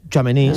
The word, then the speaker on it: Tchouaméni: ¿Tchouaméni? – Tchouaméni, sí.